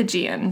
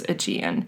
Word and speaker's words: Aegean: always, Aegean, too.